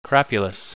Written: crapulous

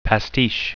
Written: pastiche

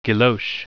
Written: guilloche

